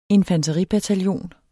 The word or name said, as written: infanteribataljon